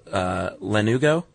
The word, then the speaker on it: lanugo: What you have in the womb are little tiny hairs called lanugo.